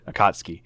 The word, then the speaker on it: Akatsuki: Akatsuki. – It's like Toyota, but it's Akatsuki. – That, okay. – Yeah, it's kind of like how you say Buick as Buick, but you say Akatsuki as Akatsuki.